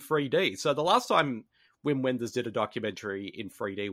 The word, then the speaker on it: Wim Wenders: and that's Wim Wenders, and I'm probably going to butcher the name, so please forgive me. – You got it right.